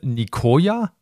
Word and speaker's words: Nicoya: Du wohnst auf der Halbinsel Nicoya, habe ich das richtig gesagt? – Genau, ja.